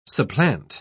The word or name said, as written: supplant